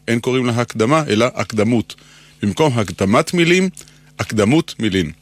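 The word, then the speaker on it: אקדמות: אמרנו שהפיוט נקרא הקדמות, על שם מילת הפתיחה שלו, אבל כבר שמעתי מבטאים זאת הקדמות, כביכול צורת ריבוי. חלק מהטעות קשור לשיטת היידיש, שהרי היידיש הדגישה לא את העברה האחרונה, אלא את שלפני האחרונה. לא הקדמות, אלא הקדומס. מנסיבות שההברה האחרונה לא הודגישה, התעמם הצליל המדויק שהיה בה. במקום צרות אמרו צורס, במקום בהמות בהמס, ולהבדיל במקום הקדמות אמרו הקדומס. התעמם הצליל השונה שהיה בהברה האחרונה. באנו להשיר היום את ההגייה על מכונה.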